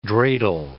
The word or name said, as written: dreidel